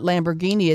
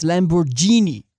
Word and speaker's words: Lamborghini: And it's not Lamborghini, it's Lamborghini.